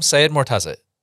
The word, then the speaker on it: Seyedmortaza: I'd like to welcome today's guest, one of our software engineers here in the United States, Adam Seyedmortaza.